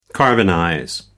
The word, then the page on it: carbonize: American English